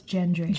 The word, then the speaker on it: Gendry: I'm going to pronounce his name wrong. Gendry? Or is it... – I thought it was Gendry. – Gendry, okay. – Gendry.